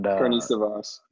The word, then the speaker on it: Karnisovas: Karnisovas.